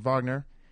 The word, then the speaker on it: Wagner: Sorry, um, Franz Wagner. I always say his name wrong. Wagner.